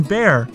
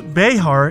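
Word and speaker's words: Bexar: and even in Bear, the county in Texas where San Antonio is located, pronounced Bejar in Spanish.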